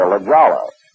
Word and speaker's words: La Jolla: It's like, how do you pronounce L-A-J-O-L-L-A? People say La Jolla. Well, it's actually La Jolla.